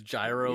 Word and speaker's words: Gyro: I'm pretty sure it's Gyro. Yeah. Like, if you were to take the kana and romanize it, I'm pretty sure it's J-A-I-R-O.